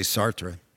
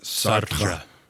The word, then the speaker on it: Sartre: I would say Sartre. – Okay. And it sounds more French, so. – But I would roll your R a little bit. Sartre.